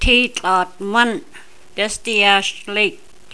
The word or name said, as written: Dezadeash Lake